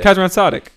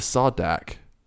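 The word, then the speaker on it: Kazran Sardick: But you don't have to pronounce the as dick. Her mom, as it's not as Kazran Sardik. It's just Sardik. Kazran Sardik. – Fuck, I thought it was Sardak.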